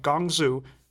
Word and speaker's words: Guangzhou: Chinese destruction of opium and prohibition of British trading ships in the main trading site of Guangzhou... Did I pronounce that right? – It's pretty good.